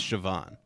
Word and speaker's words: Siobhan: Siobhan. S-I-O-B is Siobhan.